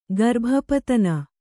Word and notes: ಗರ್ಭಪತನ: kannada

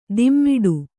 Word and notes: ದಿಮ್ಮಿಡು: dimmiḍu

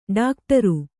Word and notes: ಡಾಕ್ಟರು: ḍākṭaru

